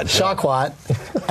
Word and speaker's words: Shawkat: And they just told me that the Aubrey Plaza episode, along with Alias Shawkat, how do you pronounce it? Shawkat.